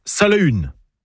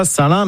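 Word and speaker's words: Salaün: Alors, déjà en breton, on prononce Salahune. pas Saint-Lin, mais Salahune, comme le célèbre ermite de la forêt de Landévennec, ou encore du Folgoat, près de l'Esnevin.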